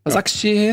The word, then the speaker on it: Başakşehir: Ähm, stattdessen hat Gladbach Wolfsberg bekommen und die Roma und äh, Basakishie. Habe ich das richtig ausgesprochen? Basakshir. Ja, das klang gut.